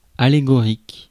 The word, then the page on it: allégorique: France; IPA: /a.le.ɡɔ.ʁik/